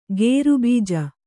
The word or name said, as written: ಖರಬುಜ